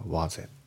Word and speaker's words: was it: で発音ですけど、まず was it のところがリンキングが起きてて、さらに it の t がね抜けますね。だから was it、 was it じゃなくて was it。